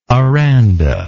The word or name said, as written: Aranda